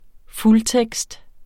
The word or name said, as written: fuldtekst